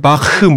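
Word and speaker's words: Bach: 우리는 보통 제가 알기로는 한국에서는 바하라고 표현을 하고 아마 원어 발음엔 바흐 뭐 이런 느낌이 되겠죠 아마.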